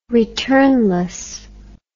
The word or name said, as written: returnless